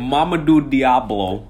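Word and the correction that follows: Hamidou Diallo: But Hamadou Diallo. Get the name right.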